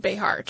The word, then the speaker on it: Béjar: The original word, Béjar, being Spanish, was most likely pronounced Béjar, treating the X like a J to sound like ye, ye, or ha.